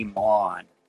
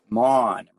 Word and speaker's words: Maughan: Lenny Maughan. Maughan. Rhymes with Sean. One syllable.